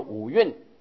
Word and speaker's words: 五蕴: 经文都是按照旧义叫做阴阳的阴的阴字念印，念起来总觉得不是很顺口，字虽然是印字啊，我讲的时候平常时往往会念作五蕴，因为五蕴新一家就翻作五蕴，五蕴皆空，般若心经里面的五蕴皆空就是这个啊。